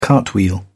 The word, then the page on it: cartwheel: British English